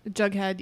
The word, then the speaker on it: Jughead: Did I say his name right? – Yeah. – Jughead, yeah.